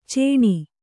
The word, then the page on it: ಚೀಣ: Kannada